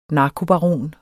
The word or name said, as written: narkobaron